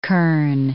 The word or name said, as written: kerne